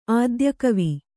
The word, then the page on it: ಆದ್ಯಕವಿ: ādyakavi; kannada